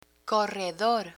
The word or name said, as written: corredor